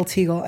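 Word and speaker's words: Tygiel: I'm everywhere at Yael Teagle, and I can spell that if I need to.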